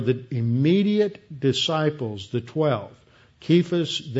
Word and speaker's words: Cephas: And that he was seen by Cephas, or actually it should be pronounced Kephas, because in Greek there was no uh, soft, uh, soft C, so it's a K that is actually in the original, so it should be translated with a hard uh, guttural sound, Kephas, then by the 12.